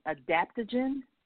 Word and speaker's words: adaptogen: And it's called, it's spelled M-A-C-A, maca. And it's called, it's considered an adaptogen. So I'm saying like the word adapt, like the adapt to something, a gen.